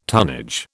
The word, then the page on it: tonnage: British English